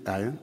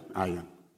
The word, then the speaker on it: Ian: There's this short story, I deleted it from my sermon, but a young man, his name is I-A-N, is it Ian? Ian. I-A-N, how do you pronounce I-A-N?